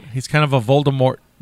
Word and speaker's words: Voldemort: She never wanted the T pronounced. We learned that when we were doing our Harry Potter Star Wars podcast. Uh-huh. It was always supposed to be Voldemort, like Voldemort. Yeah. And then people said T.